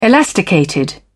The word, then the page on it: elasticated: British English